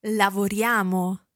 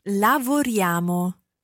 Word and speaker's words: lavoriamo: Listen again to how that's pronounced. – Lavoriamo. – Now, you'll be used to seeing this written down, I-A-M-O, at the end, but notice how it almost sounds like a Y-A-M-O.